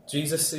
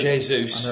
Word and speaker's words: Jesus: What do you think of Jesus, are we calling him? Sorry. – Jesus. – I pronounce it wrong.